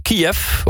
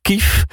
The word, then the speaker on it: Kiev: We hadden het net even over Kiev. Of Kief. Hoe je dat nou precies moet uitspreken. Omdat het valt ons op. Dat de laatste tijd jij en je collega's allemaal Kief. Of ja, Kief zeggen. Hè? Ja, jullie zeggen Kief.